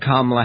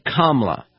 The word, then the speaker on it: Kamala: But that ad also uses the term a phony Kamala, though unlike some hosts on Fox, they do pronounce her name correctly. It's Kamala, as in Kamala, right? That's not Kamala and not Kamala. It's Kamala.